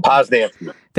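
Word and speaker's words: Posnanski: And shout out to Joe Poznanski. I can never get the name right. Joe Poznanski.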